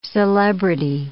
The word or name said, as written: celebrity